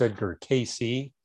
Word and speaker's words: Cayce: Yeah, usually it's pronounced Edgar Cayce.